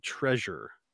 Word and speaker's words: Trezor: The two big ones right now, in my experience and my understanding, is Ledger, a company called Ledger, and a company called Treasure, but it's spelled T-R-E-Z-O-R.